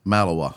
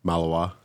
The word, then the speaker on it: Malinois: Malinois. – I can't even say it right. Oh, God. So, say it again? – Malinois.